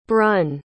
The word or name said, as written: brun